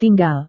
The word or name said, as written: tinggal